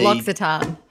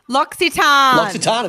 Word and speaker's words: L'Occitane: it's like L apostrophe O C I T. – L'Occitane. – L'Occitane, of course. Yeah, that's how I always say it. L'Occitane.